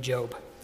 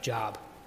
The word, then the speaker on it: Job: He gives us three examples: a farmer, a prophet, and a person named Job, which, if you look at his name, looks like Job. So Job is how that's pronounced.